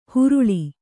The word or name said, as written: ಹುರುಳಿ